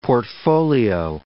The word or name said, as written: portfolio